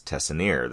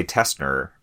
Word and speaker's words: Tessenier: Yeah, my last name is funny, too, because it's spelled, so, like, the way it probably should be pronounced is Tessenier. That's actually how it's spelled. So, like, if you, you know, think out phonetically, that's how you would say it. – That's how I would say it, too. – Yeah, yeah, and like, I say Tessner because that's how I grew up with my family saying it.